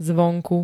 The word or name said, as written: zvonku